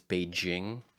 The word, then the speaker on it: Beijing: Beijing, right. But that's what they say in America, but really it's Beijing.